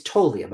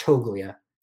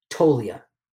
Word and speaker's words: Toglia: It's Toglia, by the way, not Toglia. There's a G in there, but we don't pronounce it. It's actually Toglia.